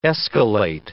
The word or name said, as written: escalate